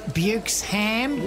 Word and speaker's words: Buchesham: Well, I just got caught chatting with Nurse Buxom. – It's pronounced Bukesham. – Well, it's spelt Buxom. – B-U-C-H-E-S-H-A-M. Bukesham. It's Icelandic or something.